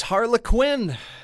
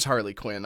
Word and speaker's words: Harlequin: There's Innocence, it's Harlequin, which I always thought it was Harley Quinn. I've been saying it wrong forever.